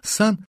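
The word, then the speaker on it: cent: Легко понять, что, скажем, французский язык, где «сто» звучит как «сан», причем слово это пишется как «сент», принадлежит группе западной, а русское «сто» — «сотня» — к восточной.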